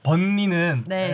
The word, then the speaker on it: 번리: 왜 니은을 니을로 발음하나요? 네. 벌리라고? – 지금 셰필드는 이제 강등이 확정돼서 조금 동기부여가 약할 수 있지만 아직 번리는 잔류 가능성이 남아있기 때문에 동기부여가 충분하거든요.